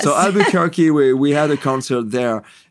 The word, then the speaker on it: Albuquerque: It's Albuquerque. – Albuquerque, yes.